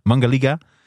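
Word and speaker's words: Mangalica: Het Mangilaka varken. Hoe spreek je dat uit? Mangilaka varken? – Oh, wauw. Mangilaka, ja. Mangal Mangalika. nee, serieus. Ja, Mangalika. Het Mangaliga-varken kan bijna concurreren met een schaap.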